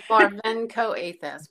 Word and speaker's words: Marvenko-Athas: It's Marvenko-Athas, but that's fine.